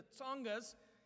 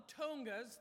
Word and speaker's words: Tsongas: It's not the Tongas. The Tongas are another people group in Zambia. We are ministering to the Tsongas. So think of the last syllable in cats, and that's the sound you put at the beginning, the Tsongas in rural South Africa.